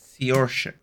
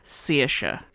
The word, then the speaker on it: Saoirse: Do you want me to spell it? I can spell it. – Yeah. – S-A-O-I-R-S-E. – Sesha. – Sesha. – I'm trying with this. Get you weird names. – It's not a weird name. It's a real Irish name.